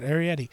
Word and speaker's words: Arrietty: The Secret World of Arrietty. Did I say it right? – That is right. Yep. – Oh, that is right. Arrietty. The Secret World of Arrietty, which is a Studio Ghibli film.